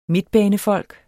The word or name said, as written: midtbanefolk